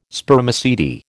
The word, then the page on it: Spermaceti: American English